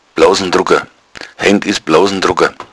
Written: blousndrugga

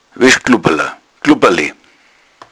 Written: glubberla

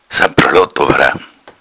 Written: sappralott

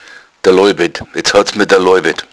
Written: daloibed

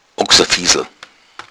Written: oxafiesl

